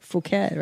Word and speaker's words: Phuket: But everybody calls it fuck it Thai. – Of course. – It's so funny. – Yeah. Well, you know, that's not how you say it, though. – Phuket, right? – Yeah, yeah. – Phuket. Yeah. Phuket Thai.